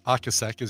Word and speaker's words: Ocasek: God, it's not even like Greg Hawkes or Rick Ocasek, or Ocasek as you're supposed to pronounce it, from, yeah, The Cars.